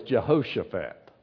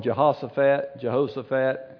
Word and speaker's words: Jehoshaphat: Now Jehoshaphat, now you can pronounce his name different ways. The right way is Jehoshaphat. I've called him Jehoshaphat, and I might do that in this message, but that's the right way, so you know the right way.